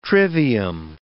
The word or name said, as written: trivium